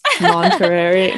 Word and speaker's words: Monterey: Monterey, Mont Monterey. It's like the cheese.